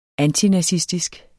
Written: antinazistisk

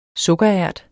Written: sukkerært

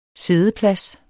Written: siddeplads

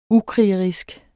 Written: ukrigerisk

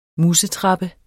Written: musetrappe

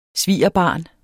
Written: svigerbarn

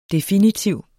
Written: DEFINITIVE

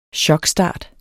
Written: chokstart

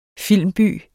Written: filmby

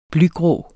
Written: blygrå

